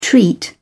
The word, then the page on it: treat: British English